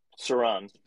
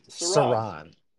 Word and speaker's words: Saron: Saron. – Yeah. – Like the Eye of Sauron? – No. I think that's Sauron. But this is with a Sauron.